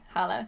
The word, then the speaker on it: Halla: Yeah, I don't know how her name is spelt in the books, but in the audiobook they pronounce her name like Hala. – Yeah, it's like H-A-L-L-A, Hala. – Yeah, I think it's supposed to be Hala.